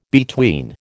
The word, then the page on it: between: American English